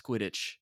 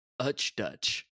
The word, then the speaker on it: Quidditch: And so what I pronounced Quidditch as Utch Dutch.